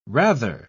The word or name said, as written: rather